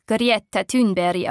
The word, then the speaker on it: Greta Thunberg: Eines Montagmorgens, während alle anderen Kinder zur Schule gehen, läuft Greta Thunberg zum schwedischen Reichstag. Im Schwedischen wird ihr Name übrigens Greta Thunberg ausgesprochen.